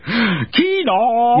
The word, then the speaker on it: Keanu: Keenaw.